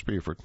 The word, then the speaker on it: Beaufort: Located on Port Royal Island in the heart of the Sea Islands that stretch from Jacksonville to Charleston, Beaufort, pronounced Beaufort, is the county seat of Beaufort County and is known for its lush low country landscape, antebellum architecture, and genteel southern hospitality.